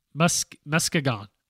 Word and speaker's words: Muskegon: It's Muskegon. I said it right.